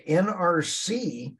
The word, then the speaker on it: NERC: Okay, NERC is the North American Electric Reliability Corporation. They're called NERC, which also rhymes with jerk. NERC. They're called NERC, rhymes with jerk.